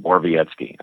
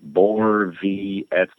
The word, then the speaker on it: Borowiecki: I said, dude, just let me hear you say your name. He's like, Borvietsky. I'm like, Borvietsky. Yeah, perfect.